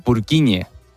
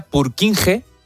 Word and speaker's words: Purkinje: Es que se escribe, en español sería Purkinje. – Purkinje. – Pero Purkinje es en checo, que es por porque es el apellido del descubridor.